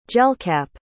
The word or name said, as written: gelcap